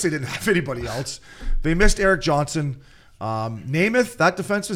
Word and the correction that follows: Nemeth: Yeah, Nemeth.